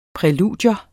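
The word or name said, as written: PRÆLUDIERNES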